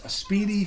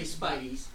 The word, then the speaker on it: spiedie: And the Speedy is spelled S-P-I-E-D-I-E. – Yes. My pet peeve is when people spell it wrong, incorrectly. – Or say Spideys.